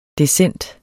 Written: decent

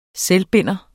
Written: SELVBINDER

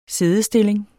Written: siddestilling

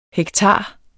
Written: hektar

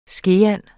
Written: skeand

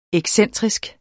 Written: excentrisk

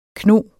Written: kno

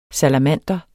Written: salamander

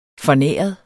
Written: fornæret

